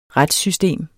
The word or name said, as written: retssystem